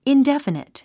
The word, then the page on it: indefinite: indéfənit; インデファニト